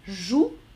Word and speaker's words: jouent: Pluriel, on prononce joue, on écrit J-O-U-E-N-T. Final.